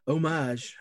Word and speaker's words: homage: It's a homage. Homage. – Homage, not homage. You said it right the first time.